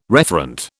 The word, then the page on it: referent: British English